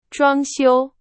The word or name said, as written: 装修